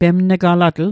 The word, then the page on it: pemn'galatl: Mi'gmaq